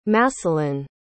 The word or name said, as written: masselin